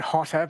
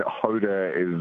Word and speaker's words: Hota: And even though we're pronouncing it Hotter, because that's the fun way to do it, it's spelt H-O-T-A, isn't it? – Yes, yes. There's no double T, but, I mean, Hoda is just, it's so particular off the top.